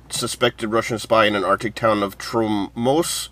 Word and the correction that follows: Tromsø: Is that how you say it? Tromso? – Tromsø.